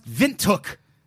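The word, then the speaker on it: Windhoek: Mirishikiari7, yeah, I think I nailed that, says it's pronounced Vintook.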